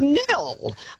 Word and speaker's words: Nhill: They cannot pronounce the silent. They say N Hill. Excuse me, it is Nil.